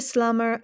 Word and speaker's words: slumber: And in the slumber of tree and stone, I'm not sure what slumber is. It's written with a B. Slumber? No, you probably don't pronounce the B, right?